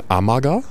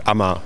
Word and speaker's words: Amager: Der Deutsche würde jetzt wahrscheinlich sagen Amager, aber ganz anders. – Ja, und der Däne sagt halt praktisch Ammer.